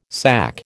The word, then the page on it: SAC: American English